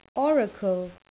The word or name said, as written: oracle